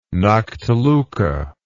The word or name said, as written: noctiluca